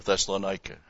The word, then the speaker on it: Thessalonica: The ekklesia of Thessalonica, or Thessalonica, however you want to pronounce it.